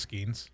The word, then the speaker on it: Skenes: Former LSU pitcher Paul Skenes, how do you say his last name? – I think it's Skenes. – Okay, Skenes, cool.